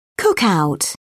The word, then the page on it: cookout: British English